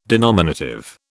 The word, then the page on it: denominative: British English